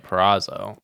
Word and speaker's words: Purrazzo: Deonna Purrazzo. Deonna. – Is it Deonna? – How would your ancestors pronounce this? Yeah. – I think you got the last name right. Purrazzo.